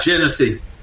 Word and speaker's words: Genesee: Genesee? – Yeah, Genesee.